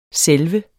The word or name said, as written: selve